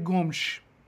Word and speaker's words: Gomes: By the way, I looked it up. I did a little research. André Gomes. Gomes. Apparently, that's the Portuguese pronunciation. Now, if you're Brazilian Portuguese, it's just Gomes. Gomes. But Gomes. No one's doing it right.